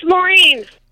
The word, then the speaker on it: Maureen: Hey, it's Maureen. – And how do you spell that? – M-A-U-R-E-E-N.